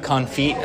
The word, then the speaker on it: confit: It's pronounced confit. – Oh, yeah. – Is he saying confit? – It's confit. – He's confit. – Yeah. Oh, confit. – The T is silent.